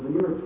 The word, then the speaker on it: Venera: Venera, that's the Russian word for Venus, and I've often incorrectly mispronounced Venera in the past. I apologize to you, whatever Russian pronounced it is Venera.